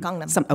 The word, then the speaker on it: Gangnam: Can you just tell people how incredibly packed with plastic surgeons Gangnam, am I saying it right? Yep. Is it Gangnam or Gangnam? – Gangnam.